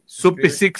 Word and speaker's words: Supicic: Supicic se escribe, ¿no? – Se escribe, sí.